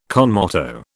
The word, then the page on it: con moto: British English